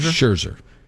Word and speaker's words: Scherzer: Am I saying his name right? – Yep. – Scherzer? – Scherzer.